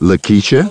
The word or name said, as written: Lakeisha